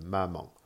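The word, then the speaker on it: mama (妈妈): Ensuite, le dernier, qui n'est pas vraiment un ton, mais euh, il existe, c'est le ton neutre. Il n'y a pas d'accentuation, euh, donc pas de marque. Il est souvent utilisé pour une interjection en fin de phrase ou sur la dernière syllabe d'un mot répétitif comme euh, maman. mama.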